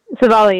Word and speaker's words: Civale: Aaron Civale. Am I saying that right? – Civale, yep.